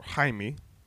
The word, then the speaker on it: Jaime: The one we know of now is Jaime Reyes, or Jaime, because that's the Spanish, that's the way that you pronounce Jamie in Spanish, is Jaime.